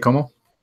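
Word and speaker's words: Comeau: It's essentially Como, right? – Blake Como. – Yeah.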